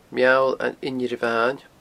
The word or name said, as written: Meall an Inbhire Bhain